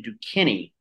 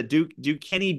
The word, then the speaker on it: Duquesne: BYU and Duquesne.